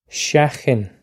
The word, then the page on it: seachain: Irish